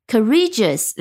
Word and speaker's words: courageous: 那么我们如果用 courageous 来造句的话，那我们注意它，当它变成了形容词，它的呃读音。和重音也有变化，变到了后面。